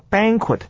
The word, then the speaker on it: banquet: 再来看到的是啊，Emily 所说的第二段话里面的第二句，There's a banquet，就是教你们这个单字，b a n q u e t 念作 banquet 哈，其实算是蛮照字面念法的啦。注意重音在第一个音节，我们念作。Banquet.